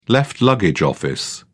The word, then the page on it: left luggage office: British English